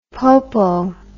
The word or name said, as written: popo